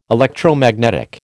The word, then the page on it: Electromagnetic: American English